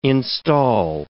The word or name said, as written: instal